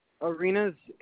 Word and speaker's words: Arenas: J-O-N-A-T-H-A-N. – And what is your last name? – Arenas, A-R-E-N-A-S.